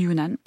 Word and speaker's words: Yunnan: À l'origine, elle contenait des Saint-Jacques ou des pétoncles séchés, des crevettes séchées, du jambon du Yunnan, je prononce bien? – Oui, jambon du Yunnan